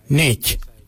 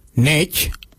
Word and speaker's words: Ngeidj: Now let's take that out, D-J-I-L, and let's put another word, which is Y-A-N-G, Nganyang. Nganyang means give me. For I, we say Nech. Nech, it's spelled N-G, E-I-D-J, Nech, I.